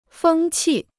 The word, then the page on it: 风气: fēng qì